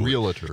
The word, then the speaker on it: realtor: Realtor. – Because everybody mispronounces it. It's realtor.